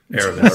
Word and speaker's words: Aramis: On today's show, we're going to talk about, we got the story trailer with Aramis. Did I say it right, Brian? – You said it right. – Aramis.